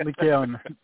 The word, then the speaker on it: Lycaon: Lycaon, Lycos is wolf, so it's Mount Lycaon.